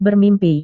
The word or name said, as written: bermimpi